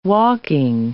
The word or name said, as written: walking